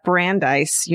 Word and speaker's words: Brandeis: My mom attended her freshman year of college at Brandeis University.